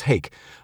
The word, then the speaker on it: hake: Uh, what was the uh, hacky, which is a big fish that's kind of like cod uh, or ha- hake. Hake, sorry, it's pronounced hake.